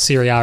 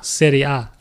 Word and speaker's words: Serie A: The only thing I love about Serie A is that the referees wear pink. – I love that you just can't pronounce Serie A. – Serie A.